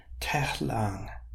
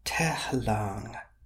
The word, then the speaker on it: tehlang: Tehlang, tehlang means whale. So that's got a long A in the second part. Lang, tehlang.